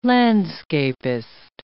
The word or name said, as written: landscapist